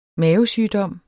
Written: mavesygdom

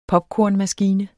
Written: popkornmaskine

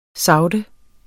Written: sagte

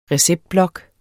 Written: receptblok

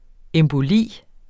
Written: emboli

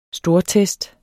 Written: stortest

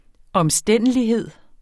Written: omstændelighed

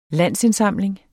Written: landsindsamling

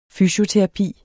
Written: fysioterapi

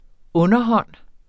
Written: underhånd

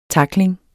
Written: tackling

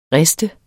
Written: riste

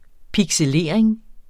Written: pixelering

